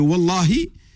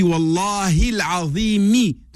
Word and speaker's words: Wallahi: Surtout le Wallah, ce, Wallahi, et euh, bien sûr, attention, le Philippe, c'est important la prononciation pour qu'un serment soit valable, il faut que tu dises la kasra, c'est-à-dire le i à la fin de Dieu. Tu dis Wallahi l'Azimi. Donc c'est le i qui fait que le waw est bien un waw al-qasam.